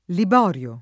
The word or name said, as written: Liborio